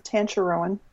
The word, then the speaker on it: Tancharoen: Anyway, like her and Marissa Tankeron and I think isn't it Tancharoen? – Yeah, I can't pronounce things.